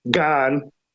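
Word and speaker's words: Gane: Jones' path to stubbing his toe and Gani's path to earning a victory, it's Gan, and I'll mispronounce it 20 times.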